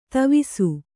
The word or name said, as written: ತಿವಿಸು